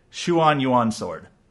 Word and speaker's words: Xuanyuan: They pronounce it Xuanyuan Sword.